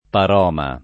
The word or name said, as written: paroma